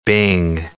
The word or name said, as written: bing cherry